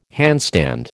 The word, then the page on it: handstand: American English